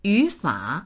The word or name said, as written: yu3fa3